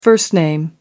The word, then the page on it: first name: American English